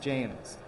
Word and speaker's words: James: Yes, he wants his first name pronounced James.